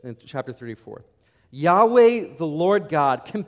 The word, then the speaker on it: Yahweh: Scholars today believe it's pronounced. And Yahweh is the more universally accepted by most scholars.